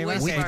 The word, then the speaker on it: Whitford: Whitford. – ¿Lo está pronunciando bien, seguro? – Sí, Whitford.